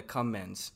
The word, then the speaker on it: Cumans: It's spelled C-U-M-A-N-S. I'm sure it's pronounced cumans, but you know we'd be calling them the cummins.